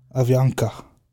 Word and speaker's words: Avianca: Avianca. A-V-I-A-N-C-A. It's like Bianca, but with an A-V instead of a B.